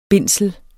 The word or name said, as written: bindsel